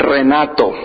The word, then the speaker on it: Renato: You know, the pronunciation in Spanish is Renato. That's the way to say it in Spanish.